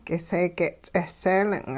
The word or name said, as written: k'iseege'-ts'eh seelin'